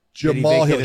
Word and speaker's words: Jamahal: Jamahal Hill.